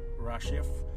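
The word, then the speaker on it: rachaph: The word that was used that for hovering is the spelling of it is R-A-C-H-A-P-H. I believe it's pronounced Rashif, which means to shake.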